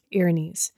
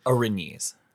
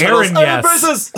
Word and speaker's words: Erinys: In my head, I pronounced it Aaron Yes.